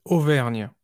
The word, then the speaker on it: Auvergne: Yes, Auvergne.